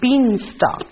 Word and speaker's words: beanstalk: Let's write the word beanstalk first. And underline the L right next to the K, because that's the word, the letter that is silent.